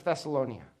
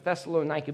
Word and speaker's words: Thessalonica: And in the book, Paul praises the church of Thessalonica. Let me say that right. Paul praises the church of Thessalonica because they are growing.